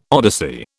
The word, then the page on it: odyssey: British English